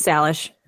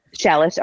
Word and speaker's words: Salish: I think it's Salish. – Salish.